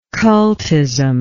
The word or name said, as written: cultist